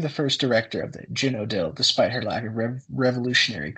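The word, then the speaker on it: Zhenotdel: Apparently it is pronounced Genodil, which I'm still probably gonna butcher because I don't speak Russian.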